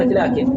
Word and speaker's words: لكن: مثل كلمة لكن، كيف نكتبها؟ لام ثم كاف ثم نون، ليس كذلك؟ مع أن هناك ألف بعد اللام لكنها لا تكتب.